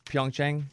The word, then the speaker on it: Pyeongchang: So you were talking about going to, how do you say it, Pyeongchang? – Mm-hmm.